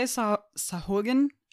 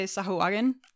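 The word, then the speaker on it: Sahuagin: People say Sahugan. I like to say Sahuagan because that's just me.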